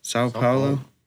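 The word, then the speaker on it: São Paulo: It's by Sao Paulo. Is that how you say it in Brazil? – Yes. – Sao Paulo.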